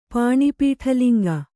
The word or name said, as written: ಪಾಣಿಪೀಠಲಿಂಗ